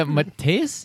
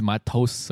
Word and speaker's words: Mateus: It's a Mateus? Yeah, Mateus. Yeah. – Yeah, there you go. – Yeah, I used to be like, oh, it's Matos or whatever.